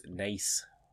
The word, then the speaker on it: Naas: I'm guessing I'm saying Naas, right? Yeah. N-A-A-S? How is it? That's how it's spelled. And I understand the Irish language. Nothing's pronounced how it's spelled. It's almost as bad as French. – I've never heard it said that way before, anyway. – Naas? – It's Nace.